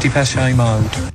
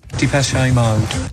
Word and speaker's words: Depeche Mode: Kommt gut in diesen noch frühen Donnerstagmorgen rein und zwar mit der Band, die viele von uns bisher anscheinend immer falsch ausgesprochen haben. Richtig ist das hier. – Depeche Mode. – Wie war's, Dave Gahan? – Depeche Mode.